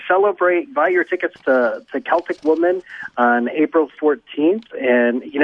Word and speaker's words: Celtic Woman: And Celtic Women, am I pronouncing that right? There's some Celtic Woman, yep.